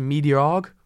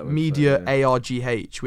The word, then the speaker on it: MediaArg: Basically, I just used a website called, I think it's pronounced MediaArg. It's like Media ARGH, which for anyone who's interested in entry-level media jobs, it's a really, really good website.